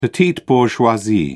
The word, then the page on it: petite bourgeoisie: British English